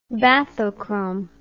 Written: bathochromic

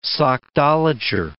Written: sockdolager

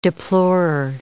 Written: deplorer

